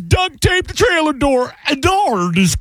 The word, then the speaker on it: Edouard: E-D-O-U-A-R-D. Edouard? – Edward? – I get, well, there's an O before the U. Edouard. Edouard!